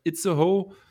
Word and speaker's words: Itzehoe: Es war, wenn sie verlieren und gleichzeitig Itzehoe.